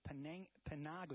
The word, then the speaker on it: Panago: I also like Colonel Panago. Is that how you pronounce it? – That's how you pronounce it.